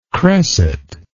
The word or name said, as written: cresset